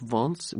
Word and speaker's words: Vence: He died in a place called, I suppose it's pronounced Vence, V-E-N-C-E, in France.